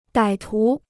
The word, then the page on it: 歹徒: dǎi tú